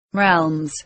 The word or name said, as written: realms